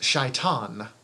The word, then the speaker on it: Satan: Which is apparently supposed to be pronounced Shaitan. Mm. But it's Anton Satan, and I'm going to call it.